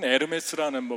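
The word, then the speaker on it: Hermès: 요즘은 에르메스라는 뭐 브랜드가 있던데 그게 에르메스 똑같은 H 발음을 에르메스라고 발음을 하니까 똑같은 것 같아요.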